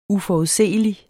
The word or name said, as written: UFORUDSEELIG